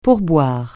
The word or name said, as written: pourboire